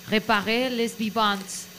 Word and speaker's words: Réparer les vivants: Otra película es francesa y se llama Réparer les vivants.